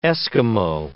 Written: Eskimo

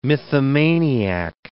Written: mythomaniac

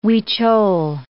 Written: Huichol

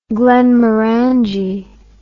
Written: glenmorangie

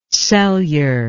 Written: celure

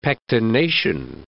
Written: pectination

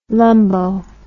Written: lumbo

